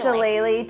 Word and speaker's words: Shillelagh: I want to cast Shillelagh to reinforce my quarterstaff.